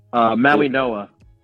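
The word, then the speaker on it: Mauigoa: Maui Noah. – Maui Noah. – There's Maui like Noah. An N in, there's no N in the last name, but that's how you say it.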